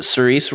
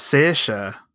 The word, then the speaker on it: Saoirse: Is it Cerise Ronan? – No, it's Saoirse.